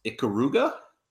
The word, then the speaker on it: Ikaruga: I believe it's pronounced Ikaruga.